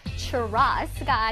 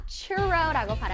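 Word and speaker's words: churro: churros가 아니라 churro라고 발음하는 거 꼭 기억할게요.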